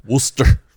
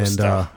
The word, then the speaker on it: Worcester: Worcester. – And Worcester.